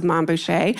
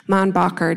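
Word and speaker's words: Mainbocher: The social set flocked to the newly minted house of Mom Boucher, a combination of his first and last name with, of course, the French pronunciation. Monbacher doesn't have the same ring.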